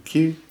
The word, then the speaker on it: Kew: There's a great little park in town called Q. Q? Q? – Yeah, K E W. Is that how you pronounce it? – K E W Q. Q. Yeah. Yeah, Q.